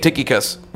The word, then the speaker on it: Tychicus: Number six, Tychicus, and I think you pronounced that one right too, Pastor Janae. Tychicus, which means fortunate one.